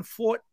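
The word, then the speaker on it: Forte: You know, I wasn't big on Fort, I guess it's pronounced. I ironically, is. Got on. – Okay. – Yeah, it is Fort, apparently.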